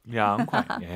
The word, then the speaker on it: 凉快: 량콰이 아니고 량콰이. 아하, 이 발음 기억하세요. – 所以奶奶又要出来了吗？ – 삼성 경성은 나이나이. 아하, 이성 경성의 대표 발음 누군지 아십니까? – 不知道。 – 할아버지. – 爷爷。 – 량콰이.